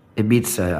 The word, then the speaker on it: Ibiza: Is that how it's pronounced, even in English? – Ibiza, I would think, is how you'd say it.